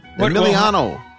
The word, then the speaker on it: Emiliano: No, you still screwed it up. Emiliano. – Emiliano. Oh, it's not Emily.